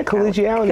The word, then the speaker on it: collegiality: Did I say that right? Collegiality? – Yeah, collegiality. – Collegiality, collegiality. – Yeah, that's right.